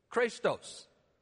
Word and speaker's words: chrestos: If you're jotting this down phonetically, it'd be spelled C-H-R-E-S-T-O-S. Okay? C-H-R-E-S-T-O-S. Christos. Christos. The word means to be helpful or to be beneficial.